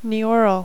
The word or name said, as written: Neoral